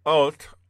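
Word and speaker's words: Ot (אות): One is translated as sign, called Ot, phonetically spelled O-T-H, and then wonder, which is Mopheth, which is spelled M-O-P-H-E-T-H.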